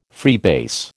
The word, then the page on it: freebase: American English